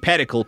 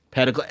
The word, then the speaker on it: pedicle: The funny thing about pedicle is everybody pronounced it like medical, to rhyme like medical, because that's what Anthony Bubenick, a famous antler researcher, said, that it rhymes with medical.